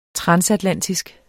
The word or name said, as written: transatlantisk